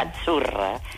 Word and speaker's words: Azzurra: Mira, ves, Azzurra. – Es que... – Azzurra. Sí, como porque pizza. La cita no es la cita como decimos en catalán tan sonora, no. Ah, es Azzurra.